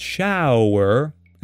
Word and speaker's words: shower: So if you look at this word, it looks like show, but has nothing to do with the show. It's a different thing. It's shower, and it's pronounced differently. Ow, not O. Shower.